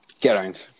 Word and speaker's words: Geraint: Geraint. – Geraint. Okay. – I have a bit more of a Welsh accent on there, but you smashed it.